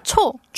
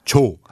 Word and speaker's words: 초: completely messing that one up now. – 초. – 초. Ah, we're talking about different syllables. – Yes. – 초. Whereas 조. – Yes. – This is 조, the softer one.